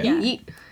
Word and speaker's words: Yeet: Is that how you say it? – Yeah. – Yeet. Y-E-E-T.